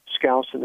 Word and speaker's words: Skousen: Skousen is spelled S-K-O-U-S-E-N.